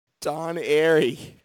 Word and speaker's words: Airey: Oh, it rhymes with Con Berry. – Con Berry. I have no idea. – Don Airey. – Don Airey, that's right.